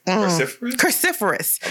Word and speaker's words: cruciferous: Is it coriferous? – Cruciferous. I believe so.